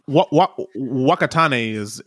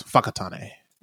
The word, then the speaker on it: Whakatane: And real quick, Whakatane is one way to say it, but the WH, I'm going to give you a little lesson in Te Reo Mori. The WH is pronounced as an F. So I believe the correct pronunciation, I was not reading, it is Whakatane is what it should be.